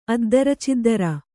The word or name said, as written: ಅದ್ದರಚಿದ್ದರ